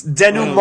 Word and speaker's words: denouement: It's denouement.